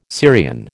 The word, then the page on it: Syrian: American English